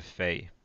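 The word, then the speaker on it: Fe: So after that, we have Fey. It's how it's pronounced. F-E is the title.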